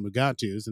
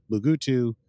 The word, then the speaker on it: Mugato: Meanwhile, Boimler and Rutherford, they're supposed to be reading up on Mugatos. And this is where Boimler points out that Mugatos, there's like several pronunciations for them. There's Mugato, Mugutu, Mugato, Gumato, and apparently a few others that we'll run into.